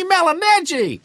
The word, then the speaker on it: Malignaggi: Malignaggi. That's how it's officially pronounced, I believe, John.